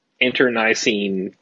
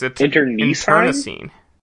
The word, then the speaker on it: internecine: 90% sure you pronounced that word wrong. – How would you pronounce it? – I don't think there's an I where you pronounced an I. – I had always heard it pronounced with an I. I know there's an E there. – I think it's internecine. – No, you're putting the emphasis on the wrong syllables there. – Well, there's definitely no... There are 2 I's in the word.